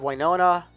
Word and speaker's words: Winona: This came out of Winona, New Jersey. Am I saying that right? I hope so. Sounds right.